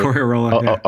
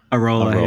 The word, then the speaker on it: Arola: Cory Arola. – Arola. Okay. – Arola. Yeah. – Like a Corolla, but Arola. Close enough, right? – Yeah.